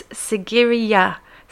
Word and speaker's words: Sigiriya: I believe it's Sigiriya Rock. Sigiriya.